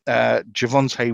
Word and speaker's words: Javonte: So, yeah, Javonte Williams with your silent D.